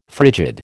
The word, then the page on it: frigid: American English